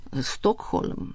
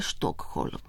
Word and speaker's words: Stockholm: Pravilno je Varšava, Stockholm, ne Stockholm.